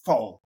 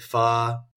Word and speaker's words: pho: But there's different ways to pronounce it. Pho or pho. – Oh. – That's like kind of the white American way to say it, I guess, but pho.